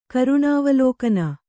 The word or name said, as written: ಕರುಣಾವಲೋಕನ